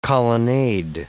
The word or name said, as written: colonnade